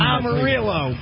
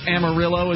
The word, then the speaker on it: Amarillo: Is it Amarillo as well? – It is Amarillo. – No, it's not. It's Amarillo. – It is not. – How do you pronounce it? – You don't get to rename a city in Texas.